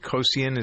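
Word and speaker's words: Kocian: Laurie Hernandez, Madison, is it Kosian? Is that how you pronounce her name? I think so. That sounds right.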